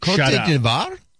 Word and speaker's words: Côte d'Ivoire: And the UN was like, Côte d'Ivoire? Yeah, that's the French pronunciation.